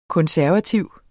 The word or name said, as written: konservativ